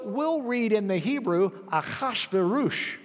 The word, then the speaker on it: Ahasuerus: So Ahasuerus is the way they were pronouncing the Shashayashashush. And then we get it into English, and the English translators say Ahasuerus, which isn't even close to the Hebrew, Ahasuerus, which isn't all that close to the Zesayasha, and Zesayasha, the Greeks had trouble with.